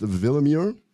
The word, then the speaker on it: Willemier: Dr. Kristen, it's spelled Willemier. It's pronounced Villamuir.